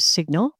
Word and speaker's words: SGNL: Hey, speaking of security, CrowdStrike acquired SGNL, which I think they probably pronounce as Signal, for identity security.